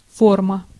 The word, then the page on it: shape: /ˈʃeɪp/; [ʃeɪp]